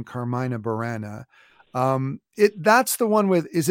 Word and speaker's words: Carmina Burana: It's pronounced many different ways. Your way was fine. I pronounce it Carmina Burana.